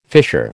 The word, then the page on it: fisher: American English